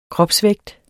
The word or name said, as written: kropsvægt